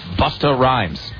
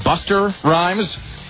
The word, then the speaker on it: Busta Rhymes: And I swear to God, there was a long, involved, very serious discussion about whether it was Buster Rhymes or whether it was Busta. Which then, and it climaxed with the chief of the director of engineering here. The director of engineering at CBS Radio's. Sort of just a really big white guy himself standing up and saying, no, it is pronounced Busta Rhymes.